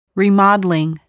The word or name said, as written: remodeling